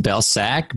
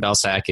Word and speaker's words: Belcak: So it's pronounced Belcak,